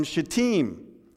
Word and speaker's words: Shittim: Then Joshua, son of Nun, secretly sent two spies from Shittim. Had to pronounce that properly.